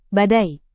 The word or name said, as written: badai